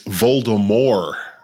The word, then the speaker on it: Voldemort: One of my children was informing the other, it's Voldemort. That's how they really pronounce it.